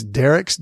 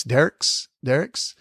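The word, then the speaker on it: Dierks: Another town nearby is, I'm not sure I'm going to say this right, it's Derrick's, D-I-E-R-K-S, Derrick's, where my wife Diane went to school.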